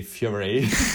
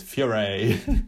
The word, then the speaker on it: Furey: Fiore. I'm saying it like this because it's spelled Furey, so it's Fiore.